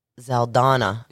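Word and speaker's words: Saldaña: How do you say her name? – Zaldana. – Zaldana? – Yeah.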